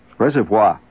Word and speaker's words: reservoir: Reservoir is the way it's pronounced. Raw, reservoir. It's not reservoir.